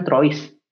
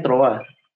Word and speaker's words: trois: Kalau bahasa Prancis misalnya uh, tro troa tiga kan tulisannya trois tuh. Kalau kalau kita baca pakai bahasa Indonesia kan trois. Nah tapi kalau aslinya bacanya troa.